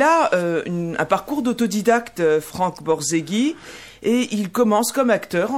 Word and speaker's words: Borzage: Et aujourd'hui, eh bien, il va s'agir de Monsieur Franck Borzaghi. J'ai appris, qu'on ne prononce pas du tout Franck Borzage. Mais non, c'est trop franco-français. C'est Borzaghi. Voilà, d'origine italienne, c'est ça? – Oui, merci Nathalie.